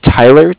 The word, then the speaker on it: Tyler: My name is Tyler, T-Y-L-E-R, and I'm calling with Cardiff. It's spelled C-A-R-D-I-F-F.